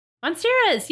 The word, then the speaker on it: Monstera: Monstera? – Monsteras, yes.